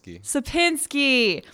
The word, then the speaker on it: Sapinski: Oh my gosh, I already butchered it. What's your last name? – Sapinski.